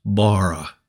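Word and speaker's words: bara (ברא): It is pronounced bara, kind of like B-A-R-A, bara, which is to create something out of nothing.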